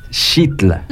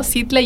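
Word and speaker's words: Xitla: Pues hay quienes, eh, es que pues están como las dos pronunciaciones se hacen válidas, ¿no? Sitla y shitla. Pero yo he escuchado más sitla, la verdad.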